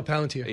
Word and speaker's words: Palantir: I do think, the CEO of Palantir said this recently, Palantir, however you pronounce it. – No, Palantir.